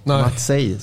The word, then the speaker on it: Maciek: Visste du att Maciek uttalas så? Matsej står det.